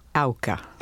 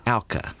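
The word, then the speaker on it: Auca: She became a widow 3 years later when Jim Elliott was speared to death by the Aka Indians. Did I pronounce that correctly, Elizabeth? – Not quite. The first syllable rhymes with cow. – Ah. – Auka. – Auka.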